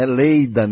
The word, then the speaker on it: Leida: É Leida mesmo, não estou falando errado não.